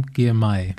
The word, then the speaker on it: Girmay: Binyam Girmay.